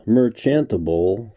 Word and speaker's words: merchantable: I mispronounced merchantable for whatever strange reason, using a hard C, as in mercantile, instead of the CH that was spelled out on the page I was reading from, and I must admit, I've never heard the word said.